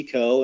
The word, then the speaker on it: Koh: Coe, and the last name is spelled K-O-H.